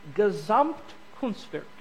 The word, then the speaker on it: Gesamtkunstwerk: And he coined a phrase in German, and it's pronounced Gesamtkunstwerk.